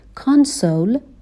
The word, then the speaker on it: console: کانسول به تلفظش دقت کنین صدای آ داره.